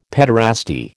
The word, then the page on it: pederasty: American English